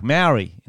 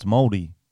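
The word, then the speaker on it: Māori: Because all the white Australians were like, Maori. It's Maori. They're like, no, it's Maldi.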